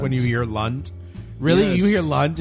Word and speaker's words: Lunt: Lunt rhymes with cunt.